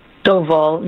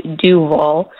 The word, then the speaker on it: Doval: But first of all, it's pronounced Doval, not Duval.